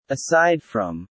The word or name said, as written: Aside from